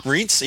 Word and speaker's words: Rients: Rients, yep, that's what I thought. Like one syllable, yeah. You just go right across.